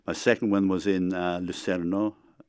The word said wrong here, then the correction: Lucena: It's in Switzerland. – It's near Cordoba. – Lucena, Cordoba. – Pronounced it incorrectly, okay.